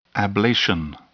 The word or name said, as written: ablation